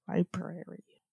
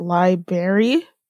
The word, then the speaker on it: library: Library. – All right. – That's a hard word. That's an interesting word, because lots of people like pronounce it library, but there's an R. Right, so it's library.